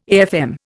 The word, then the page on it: AFM: Indian